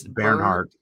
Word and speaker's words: Bernhardt: I researched that. Apparently it's like air. It's like the, it's Bernhardt. – Yeah. It's Bernhardt. Bernhardt is how you say that first name.